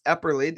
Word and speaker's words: Epperly: Listeners, this is Dr. Bruce Epperly.